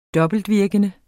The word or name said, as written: dobbeltvirkende